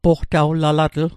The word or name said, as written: poqtawlalatl